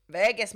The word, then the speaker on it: Verges: V-E-R-G-E-S. I'm sorry if I'm not saying it correctly. – Verges, mate. – I'd say Verges.